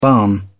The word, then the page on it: barn: British English